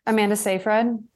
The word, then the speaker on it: Amanda Seyfried: Amanda Seyfried.